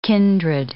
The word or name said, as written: kindred